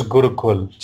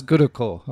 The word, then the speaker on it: Gurukul: How do you pronounce that, your academy? – It's Chess Gurukul. – Chess Gurukul. Okay.